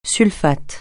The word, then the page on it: sulfate: francais